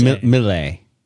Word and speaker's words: Miley: It's pronounced Miley.